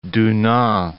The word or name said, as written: Dunant